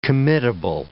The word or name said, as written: committable